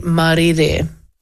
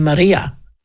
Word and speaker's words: Marire: Now, when I was growing up, I got my own middle name wrong. I thought it was Rangi Maria or Rangi Marie. But Rangi, as my husband goes, Rangi Marie. I said, well, it's not quite Rangi Marie, but it's Marire, which is so long.